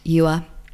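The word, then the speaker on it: dứa: So pineapple and watermelon are both yua, which is spelt D-U-A, but the D is spelt, pronounced like a Y, you, okay.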